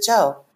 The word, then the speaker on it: Chou: So I was like, Well, it's Ruth Joe. And now um, it's nobody could possibly know that unless you really Google Chinese pronunciation of C H O U.